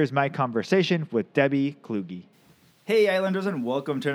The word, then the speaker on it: Kluge: It's Kluge with a hard G and a long E, but that's okay. – Kluge.